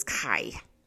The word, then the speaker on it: Kay: the way it's pronounced is Kai.